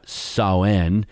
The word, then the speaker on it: Samhain: Um, the pagan festival, which is uh, spelled S A M H A I N, but it's actually pronounced uh, Sauen, celebrated the final harvest.